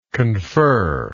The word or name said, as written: conferer